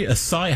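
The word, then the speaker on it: açaí: And Dan wanted a strawberry acai. How do you say it? – Acai. – Acai. Acai. A refresher.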